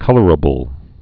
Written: colorable

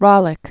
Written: Ralik Chain

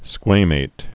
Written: squamate

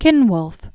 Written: Cynwulf

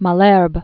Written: Malherbe

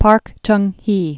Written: Park Chung Hee

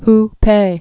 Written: Hupeh

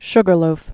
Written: Sugarloaf Mountain

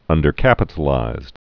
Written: undercapitalized

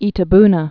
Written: Itabuna

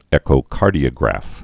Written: echocardiograph

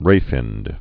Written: ray-finned fish

